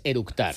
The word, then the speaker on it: eructar: y lo primero que hizo es orutar. – Con orutar es eructar.